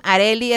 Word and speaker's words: Arelly: Y les digo, me estaban preguntando aquí las redes, en TikTok, Megabesos Locutora, en Instagram, Areli, es con doble L-Y mi nombre, se escribe A-R-E-L-L-Y, Areli, Areli Alcocer, locutora holística, y en Facebook estoy como Areli Alcocer, locutora y terapeuta.